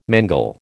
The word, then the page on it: mangel: American English